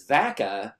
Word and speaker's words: Vaca: So growing up in Northern California, it was very easy to say that my last name was Vaca, like Vacaville, and everybody knew exactly, they knew how to spell it, they knew what you were talking about.